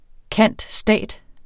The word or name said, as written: cand.stat.